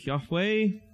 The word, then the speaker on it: Yahweh: The name itself is made up of, as I mentioned, four Hebrew characters, which roughly translate to English YHWH, makes for a rough pronunciation of Yahweh.